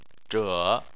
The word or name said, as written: zhe3